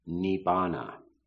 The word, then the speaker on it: nibbana: I'm from the Theravada tradition, the early suttas, and the word which is commonly pronounced nirvana in Sanskrit is pronounced nibbana.